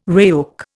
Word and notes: RAOC: Indian

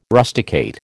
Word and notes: rusticate: American English

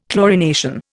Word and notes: chlorination: Indian

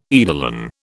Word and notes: eidolon: British English

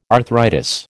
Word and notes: arthritis: American English

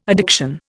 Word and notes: addiction: Indian

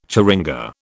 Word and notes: churinga: British English

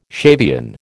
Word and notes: Shavian: American English

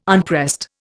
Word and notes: unpressed: Indian

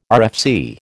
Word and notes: RFC: American English

